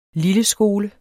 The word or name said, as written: lilleskole